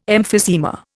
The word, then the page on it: emphysema: Indian